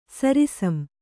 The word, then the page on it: ಸರಿಸಂ: Kannada